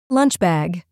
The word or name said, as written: lunch bag